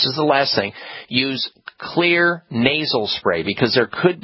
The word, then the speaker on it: Xlear: It's spelled X-L-E-A-R. The X is for xylitol, because it has a lot of xylitol in it, which is good. And so what you're basically doing is clearing your nose with clear nasal spray, spelled with an X, X-L-E-A-R.